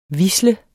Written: HVISLEDE

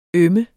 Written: øm

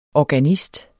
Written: ORGANISTEN

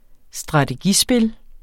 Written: strategispil

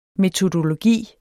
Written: metodologi

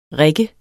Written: rigger

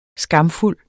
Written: SKAMFULDE

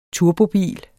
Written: turbobil

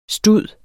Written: STUDENES

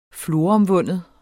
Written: floromvunden

